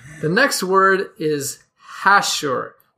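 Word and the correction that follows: hachure: No, hashur. Emphasis on the second syllable.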